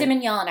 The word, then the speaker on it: Simonyan: How do you pronounce her name? – Simonyan, I believe.